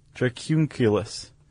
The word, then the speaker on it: Dracunculus: And like we said, it filters out, do you remember how to say this, Chuck? Is it dracunculus? Dracunculus. Dracunculus. That's what it is.